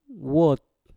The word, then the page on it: wot: mpur